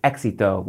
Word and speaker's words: éxito: The word for success in Spanish is éxito, with the accent on the E.